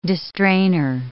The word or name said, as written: distrainor